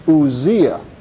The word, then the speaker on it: Uzziah: Now, when we get into our study in the book of Chronicles, and as well as mention of him in Isaiah, it is pronounced Uzziah. They're spelled the same in Hebrew except for one letter, and many people believe that a scribal error has caused the English to come out a little different.